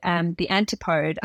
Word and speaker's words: antipode: And it's actually what's called the antipode. I don't know if I'm saying that right. – That's correct. Yep.